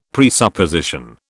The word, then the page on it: presupposition: British English